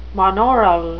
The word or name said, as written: monaural